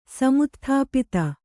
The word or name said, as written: ಸಮುತ್ಥಾಪಿತ